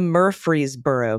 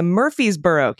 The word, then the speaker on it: Murfreesboro: He says start with Fresno, as in California. Change that to Fresboro. Add the mer to the beginning, Murfreesboro, and make that first syllable the most emphatic one.